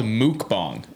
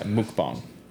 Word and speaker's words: mukbang: It's also called mukbang, apparently. That's how it's pronounced. – I think that's the right pronunciation. – Yeah, mukbang.